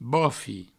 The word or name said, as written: bofi